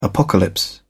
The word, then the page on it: apocalypse: British English